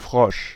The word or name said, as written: Frosch